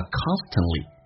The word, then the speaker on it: constantly: T 跟 L 我刚刚有讲过 T 可以不发音叫 constantly